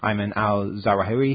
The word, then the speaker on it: Ayman al-Zawahiri: He introduced, in the rest of the 40-minute or so talk that he gave, he introduces Al-Qaeda's second-in-command, Ayman al-Zawahiri. Is that how you pronounce it? Yeah.